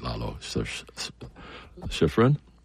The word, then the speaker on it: Lalo Schifrin: Lalo, I'll say it right, Lalo Schifrin.